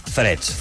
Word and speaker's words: Threads: Tu deus saber com es pronuncia això. – Freds, freds. Freds, no? Hem anat dient frits i estrets. Sí, freds, Va, doncs, a, a meu oberta.